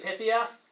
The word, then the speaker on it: Phthia: P-H-T-H-I-A. We usually say Pythia, just being sort of lazy, but it's Pythia.